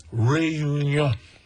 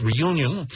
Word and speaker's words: Réunion: Réunion. Which, of course, is pronounced in English, Reunion.